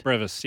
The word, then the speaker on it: Brevis: Brevis, yeah.